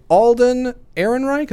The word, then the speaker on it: Alden Ehrenreich: Alden Ehrenreich, is that how you say it? – That's right.